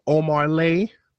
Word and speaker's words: Omah Lay: Omarlay. – Hey. – Omarlay. – Omarlay. I like that. It works for me. – Where'd the R come from in Omar?